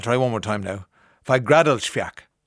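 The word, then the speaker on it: Fagradalsfjall: Fagradalsfjak. Fagradalsfjak. Fagradalsfjak. Fagradalsfjak. I think I have it. I think I have it. That's the pronunciation of an Icelandic volcano that has erupted over the weekend.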